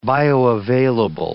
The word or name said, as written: bioavailable